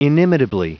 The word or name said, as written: inimitably